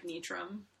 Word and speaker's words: Nitram: It's following totally straightforward, like, English pronunciation, but, like, I say it like Nitram.